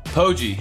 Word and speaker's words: Poggi: Poggi.